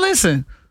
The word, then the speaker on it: listen: Listen is another one where the English words has a silent letter. – Silent and listen. – T. Listen. – Listen. – You don't hit no T in listen.